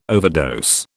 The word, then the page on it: overdose: British English